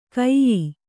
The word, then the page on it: ಕೈಯೀ: kannada